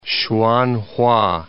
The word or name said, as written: Hsuan-hua